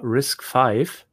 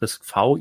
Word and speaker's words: RISC-V: Aber ähm, wir haben heute ein spannendes Thema und zwar Risk 5 oder Risk V, je nachdem, wie man es aussprechen möchte.